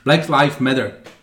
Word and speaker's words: Black Lives Matter: Tvoje výslovnost je šílená, teda bych chtěl říct. Black, ty jsi to opravdu vyslovil jako Black Lives Matter. Což je hrozný. to zní strašně. Black Lives Matter.